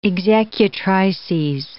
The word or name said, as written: executrices